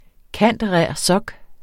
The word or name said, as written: cand.rer.soc.